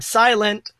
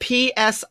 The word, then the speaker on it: Psilent: It's spelled P-S-I-L-E-N-T because the P is silent. – Oh, I would have just said P-silent. – No, the silent with a P, silent P.